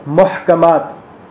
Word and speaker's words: Muhkamat: The first type is Muhkamat, the verses which are regarded as Muhkamat. Muhkamat in English is spelled M-U-H-K-A-M, Muhkam, and double A-T at the end to make it plural.